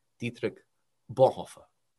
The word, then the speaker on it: Dietrich Bonhoeffer: And so I say, you know, Dietrich Bonhoeffer, and they start laughing because in that's not how you pronounce it, right? And so then they would say it with their with their German voices, you know, and it's something like Dietrich Bonhoeffer.